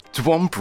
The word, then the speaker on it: De Womp: Well, De Womp really, actually rhymes with move or dump.